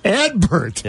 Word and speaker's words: Adbert: Edbert. – Yeah. A-D-B-E-R-T. – Yeah, like an ad burst, the old advertising phrase. But the T is pronounced.